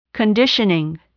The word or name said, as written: conditioning